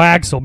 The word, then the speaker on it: AXL: formerly known as AXL, is now Axel because we were just saying it wrong because we're retarded.